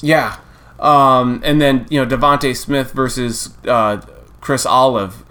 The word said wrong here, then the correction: Olave: or Ali, however the hell you pronounce his name. Olive. I would assume he likes. – I think it's Olave.